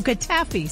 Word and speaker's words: Cattafi: Is it Cattafi? – It's Cattafi. – Oh, Cattafi.